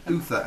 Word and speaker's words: Uther: Colwyn Uther, as in Uther Pendragon. – Yes.